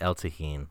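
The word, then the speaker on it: El Tajín: El Tajin? El Tajin? – Oh, don't worry about the places. – Well, it's Jake, so it'll be H then, right? So it'll be El Tajin, I'm going to say.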